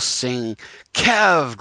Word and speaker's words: Sienkiewicz: And it has, you know, it's pronounced Bill Sing Kev, great big K-E-V itch at the end.